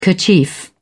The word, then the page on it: kerchief: British English